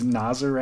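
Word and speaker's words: Nazaré: Only because two or three years ago, people started correcting us and it was Nazir or something closer to Nazir. Anyway, our pronunciations are always wrong.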